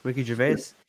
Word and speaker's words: Gervais: Ricky Gervais.